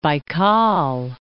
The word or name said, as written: Lake Baykal